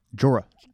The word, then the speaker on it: Jeor: Jor Mormont? – Jorah. Wait, no. – J-E-O-R. That's how his name is spelled. – J-E-O-R. – Lord Commander. – Yeah. – Okay, yeah. – Jor. Anyway, it's...